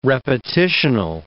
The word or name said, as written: repetitional